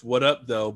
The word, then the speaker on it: What Up Doe: But there's a room, and it's called, it's What Up, though, but it's W-A, well, they spell it, yeah, W-H-A-T-U-P-D-O-E, and it's What Up, Though Wednesdays.